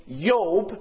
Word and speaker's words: Job: The name Job, which they don't have J's in Hebrew, it would be pronounced as a Y. So they would say Job.